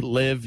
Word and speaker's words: LIV: And by the way, it's LIV, live. You can say live tour.